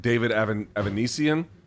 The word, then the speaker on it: Avanesyan: Again, I'm going to mispronounce his name. David Avanesyan? – That's correct.